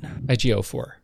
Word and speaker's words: Ejiofor: And I want to say Chiwetel Ejiofor. Did I say that right? – Ejiofor. – Ejiofor. – That's what I understand.